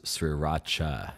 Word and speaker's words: sriracha: You know, and don't even think about freaking sending me Sri Raka to here. Okay, just listen up, Listerland. I want an Ishiraka. – I also want emails because I think it's pronounced sriracha.